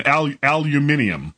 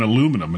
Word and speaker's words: aluminium: And aluminium. I have enough trouble saying aluminum, and now I have to say it Yeah, that extra sort of syllable in there just for the crack is a bit odd.